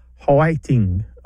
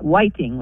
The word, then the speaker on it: hwaiting: It's often spelled H-W-A-I-T-I-N-G or P-A-I-T-I-N-G. And it sounds like three syllables instead of two. So it might be like Hawaiting or Paiting, like that. – Yes. And sometimes it sounds like whiting, like with a W almost.